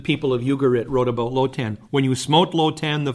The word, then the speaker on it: Lotan: Now, a similar word to Leviathan, which would be pronounced Lotan, occurs in Ugaritic mythology.